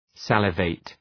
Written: salivate

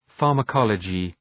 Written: pharmacology

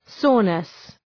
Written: soreness

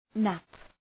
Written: nap

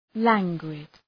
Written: languid

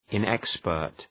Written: inexpert

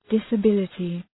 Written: disability

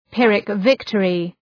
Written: Pyrrhic victory

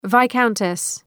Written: viscountess

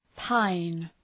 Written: pine

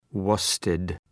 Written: worsted

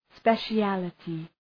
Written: speciality

